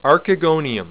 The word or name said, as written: archegonium